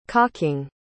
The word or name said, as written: cocking